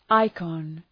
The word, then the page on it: ikon: {‘aıkɒn}